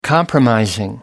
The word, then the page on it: compromising: American English